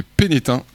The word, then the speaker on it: Pénestin: Et Pénestin. – Et Pénétin, comme on dit. – Et on ne prononce pas le S quand on connaît le patelin.